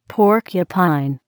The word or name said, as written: porcupine